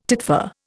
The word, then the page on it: titfer: Indian